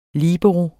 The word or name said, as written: LIBEROEN